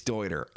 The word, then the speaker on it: Deuter: Dude, it's Deuter. I got to say that because it's spelled D-E-U-T-E-R. And sometimes I'm like, do you Deuter? How do you say this thing? It's Deuter. It's like Deuter. That's how you say it.